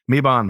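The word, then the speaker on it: Mebane: So Dee says that it's pronounced with a short A, Mebon maybe, 30 minutes west of Raleigh and 30 minutes east of Greensboro.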